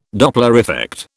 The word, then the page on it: Doppler effect: British English